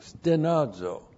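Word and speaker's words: stenazo (στενάζω): That's S-T-E-N-A-Z, long O, stenazo.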